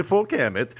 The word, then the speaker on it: folkhemmet: and I don't know if I said that right, but the folkhemmet. – That sounded pretty good.